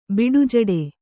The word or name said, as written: ಬಿಡುಜಡೆ